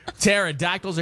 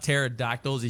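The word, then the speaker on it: pterodactyl: You know what was weird about pterodactyls is you couldn't hear them urinate? – Notice how neither of us said a word because we were waiting on the punchline. – Yeah, the P is silent.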